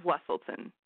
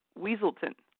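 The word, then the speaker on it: Weselton: Duke of Wesselton. Duke of Wesselton. And everyone kept pronouncing it Weaselton.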